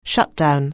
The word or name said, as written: shutdown